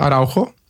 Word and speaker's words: Araujo: Araujo. Du legger trykk på U-en, og så skal du litt opp igjen på O-en. – Ja, eh, altså det skrives araujo. – Mm. – Og uttales Araujo.